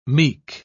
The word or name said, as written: meek